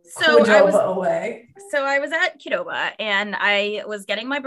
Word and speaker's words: Qdoba: Qdoba, it is Qdoba.